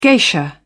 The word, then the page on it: geisha: British English